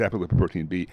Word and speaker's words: apolipoprotein B: It's apolipoprotein B, also simply called ApoB. – Okay, ApoB, apolipoprotein B, is like a mesh bag that is holding a whole bunch of fat inside of it.